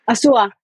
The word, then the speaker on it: Azúa: Tu nombre es Macarena Real de Asua o Asua. ¿Cómo se pronuncia? – Asua.